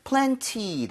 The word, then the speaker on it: plenty: 때에 따라서 여러분들 plenty 대신에 t를 약화시켜서 발음 안 하는 경우도 많아요. 그래서 plenty of. – Plenty of. – 그런 식으로 가도 괜찮습니다.